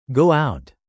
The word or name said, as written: go out